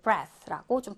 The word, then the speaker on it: breath: 자, 호흡하다, 숨쉬다는 breathe라고 발음해 주면 되고요. 명사로 breath, 요거랑 헷갈리지 마시고, 요거가 이제 호흡, 숨, 이렇게 명사로 해석이 되고, 발음도 breath라고 좀 다르게 발음이 될 수가 있습니다.